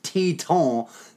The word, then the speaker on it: Titan: So, of course, in the most predictably Quebecer fashion, the Akadi Bathurst is pronounced exactly as it's spelled, and Titan is pronounced like the mountain range in Montana or wherever the Tetons are.